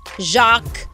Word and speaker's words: Jacques: But I will say that although the name is Wolf, they're also going by, the baby's actual name is, how do you say Jax? – Is it J-A-X? – It's J-A-C-Q-U-E-S. – Oh, Jacques. – Jacques. – Oh, Jacques. It's a French name.